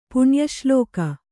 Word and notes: ಪುಣ್ಯಶ್ಲೋಕ: puṇya ślōka

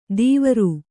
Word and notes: ದೀವರು: Kannada

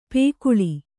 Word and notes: ಪಾಕಳ: pāk`ḷa; Kannada

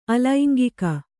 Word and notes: ಅಲೈಂಗಿಕ: alaiŋgika

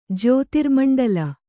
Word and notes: ಜ್ಯೋತಿರ್ಮಂಡಲ: kannada

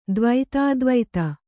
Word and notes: ದ್ವೈತಾದ್ವೈತ: dvaitādvaita

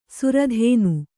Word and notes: ಸಿರಿದಿನ: siri dina

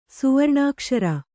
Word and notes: ಸ್ವರ್ಣಕ್ಷೀರಿ: svarṇa kṣīri

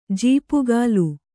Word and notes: ಝಾಪುಗಾಲು: jhāpugālu

